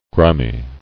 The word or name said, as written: Griminess